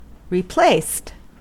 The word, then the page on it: replaced: American English